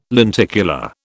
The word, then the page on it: lenticular: British English